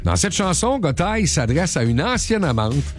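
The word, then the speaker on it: Gotye: Il y en a qui vont dire Gauthier, Gotay. – Non, Gotay, ouais.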